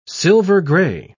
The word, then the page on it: silver-gray: British English